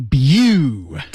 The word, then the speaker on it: debut: 注意这里有个单词、嗯、啊不知道大家知不知道 ,debut, 读音是、 yeah. debut. – debut. – 对对对对。